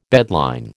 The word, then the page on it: bedline: American English